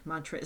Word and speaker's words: Montreal: Quebec, Montreal. Is that the right way to say it? – Yep.